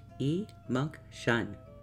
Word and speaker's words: emunction: The word is emunction. It is spelled E-M-U-N-C-T-I-O-N, and it is pronounced E-munk-shun.